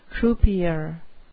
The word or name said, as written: croupier